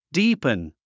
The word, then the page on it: deepen: díːpən; ディープン